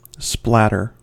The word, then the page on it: splatter: American English